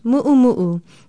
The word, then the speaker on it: mu'umu'u: It is spelled M-U-O-K-I-N-A-U, and is pronounced mu'umu'u. Don't confuse it with the word mumu, a thud-like sound. The dress is a mu'umu'u.